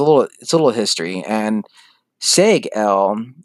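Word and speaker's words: Seg-El: But it's viewed as an insult by Seg because it's like, you know, dude, you're saying my name wrong. It's Seg-L. He's like, oh, hello, Siegel.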